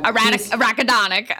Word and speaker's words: arachidonic: how do I pronounce this? Arach, arachidon. Oh God. – arachidonic. – All right. Sorry. Thank you. Arachidonic.